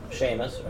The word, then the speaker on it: Seamus: And I'm playing, I always pronounce it wrong. Seamus, right? – Yep.